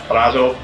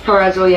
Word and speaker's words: Purrazzo: And of course, Deonna Purrazzo, I pronounced her name right. – Purrazzo, yes.